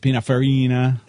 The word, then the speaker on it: Pininfarina: And then there's all those guys in Italy, Ferrari, you know, Agnelli, Bertone, Pininfarina. – Hey, be careful. – Did I say that right? – Yeah.